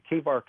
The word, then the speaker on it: Chebar: It's spelled C-H-E-B-A-R, the Kevar Canal.